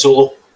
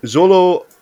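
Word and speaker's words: Xolo: is it Zolo Man? – I think it's Zolo. – Zolo Mariduena is his name.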